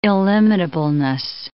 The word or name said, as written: illimitableness